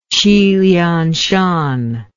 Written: qilianshan

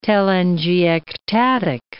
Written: telangiectatic

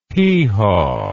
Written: heehaw

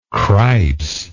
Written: cripes

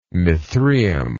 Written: mithraeum

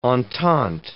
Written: entente

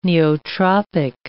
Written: neotropic

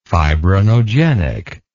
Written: fibrinogenically

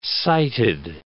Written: sighted

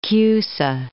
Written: Nicholas of Cusa

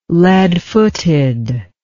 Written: leadfooted

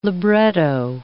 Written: libretto

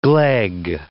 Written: gleg

